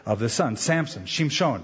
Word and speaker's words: Shimshon: Now I didn't say it right. It's it's Samson in English, but in Hebrew, Shimshon, which is a word that means of the sun or sunny boy.